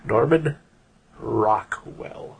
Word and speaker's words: Norman Rockwell: Norman Rockwell.